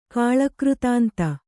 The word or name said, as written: ಕಾಳಕೃತಾಂತ